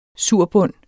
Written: surbund

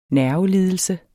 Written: nervelidelse

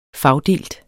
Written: fagdelt

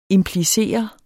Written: implicere

